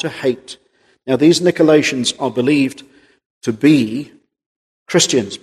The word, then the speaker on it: Nicolaitans: Nicolaitans, some would pronounce it, Nicolaitans.